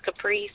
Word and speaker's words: Caprice: It's Caprice Scott, and I have like rainbow beads on.